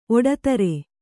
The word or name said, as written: ಒಡತರೆ